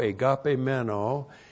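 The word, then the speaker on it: agapemeno (ἠγαπημένῳ): In His Son, en to huio autu, in His Son, 1 John 5:11, and then we have en to agapimeno. And that's the preposition and, then the definite article in dative, to. And then long E, G, A, P, long E, M, E, N, O, long O. Ento agapemeno, in the Beloved.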